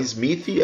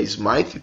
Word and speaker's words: Smythe: Duhan Smythe, não é Smythe, é Smythe também, aprendi a pronúncia desse infeliz.